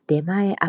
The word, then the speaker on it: 出前: 네마에 아프리 배달 앱은 네마에 아프리 네마의 아프리. 네마에는 나가다 출구의 출자에다가 어, 전후의 전자 앞이란 뜻의 전자를 쓰고요. 어, 그냥 한자를 직역을 해서 읽으면 출전이라고 되죠. 근데 일본어로는 네마에라고 읽어요.